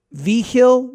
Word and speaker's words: Vigil: Vigil, V-I-G-I-L. I think it's the G is not a hard G.